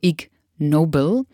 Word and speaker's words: Ig Nobel: Also ich hoffe, dass ich jetzt hier den Wortwitz mit ausspreche, denn es ist geschrieben wie ein Vor- und Nachname, also Ig Nobel. Auf Englisch heißt ignoble ja das Gegenteil von noble.